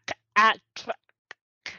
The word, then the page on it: Atraxa: Phyrexian